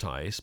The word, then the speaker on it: Schultheis: I think I've pronounced his name right, finally, although it's certainly not spelled Tice, but it's pronounced Tice.